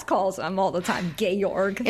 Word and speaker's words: Georg: It's spelled like George, but it's pronounced Georg, I guess.